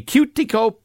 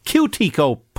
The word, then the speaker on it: Quetico: Not the cuticle polka. That would be about fingernails. This is the Cutico Polka. I'm not even sure that's how you say it. Q-U-E-T-I-C-O. Cutico Polka. Sounds reasonable to me anyway.